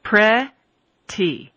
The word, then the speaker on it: pretty: Think about the pronunciation. Pre-ti. If I slow it down, I'm going to pronounce it as a T. But there's those two vowel sounds there, e, e, and the voiceless T will change to a D sound in American English because of the fact that the consonant is surrounded by two voiced sounds.